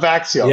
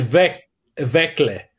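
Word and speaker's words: Växjö: Yeah, it's not Vaxio. – Yeah. No, it's not Vaxio. It's Vekle.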